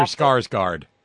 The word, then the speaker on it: Skarsgård: No, seriously, I'm not saying it because there's a stereotype, because his name is Skarsgård or something like that. – Yeah, Skarsgård. Alexander Skarsgård. – Right. – Alexander Skarsgård. Right. Rhymes with Lars.